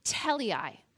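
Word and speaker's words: teleios: The Greek word, actually, for perfection, it's actually pronounced teleai.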